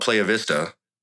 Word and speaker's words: Playa Vista: So I went to, there's an area called Playa Vista in LA. – Sure. – There's nice Whole Foods there. – Listen, I'm not some Spanish expert. I'm confident it's pronounced Playa Vista, though.